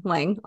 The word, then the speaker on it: Lange: So we have Sarah Lane on today. Oh shoot, now I said it wrong. Lange on today.